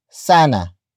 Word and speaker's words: sana: The next word is sana which is spelled as S A N A.